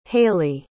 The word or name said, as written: haley